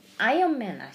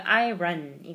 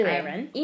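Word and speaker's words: iron: 쌤, 아이언맨 아시죠? – 알죠. – 우리 그거 발음을 아이언맨 이렇게 하지만 네네. – 정확하게 하면 아이언 아니고요. 아이런 이거든요. – 네네. – 아이런.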